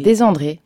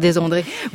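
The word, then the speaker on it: Desandre: Je ne sais pas si je dois dire Desandré vraiment à l'italienne, comme votre nom originel doit se prononcer, ou à la française Desandré. – Mais... Desandré. – Vous vous dites Desandré. Alors je resterai sur Desandré. Vous incarnez Alcyone dans l'opéra de Marin Marais qui se joue à l'opéra comique en ce moment.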